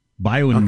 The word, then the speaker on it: BioNTech: Pfizer and BioNTech. B-I-O, bio, then N, then tech. BioNTech. Okay. I don't know how you pronounce it. – BioNTech. It's like a shop and save.